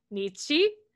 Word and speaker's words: Nietzsche: Okay, your idol is Nietzsche. Did I say that right? – Yeah.